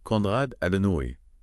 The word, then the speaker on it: Konrad Adenauer: Et prononciation allemande Konrad Adenauer.